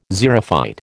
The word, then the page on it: xerophyte: American English